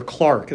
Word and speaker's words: clerk: He thought he saw a banker's clerk, or Clark, as they would have pronounced it, descending from the bus.